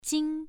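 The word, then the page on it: jing: tone 1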